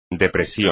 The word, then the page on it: depresión: espagnol